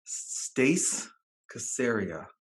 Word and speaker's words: Stace Caseria: We're grateful to have with us Stace Caseria.